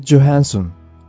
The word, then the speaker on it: Johansson: スカーレット・ジョハンソン。これが英語では正しい読み方のようですね。